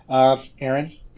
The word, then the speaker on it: Arron: And my co-host is, Aaron Berrigan.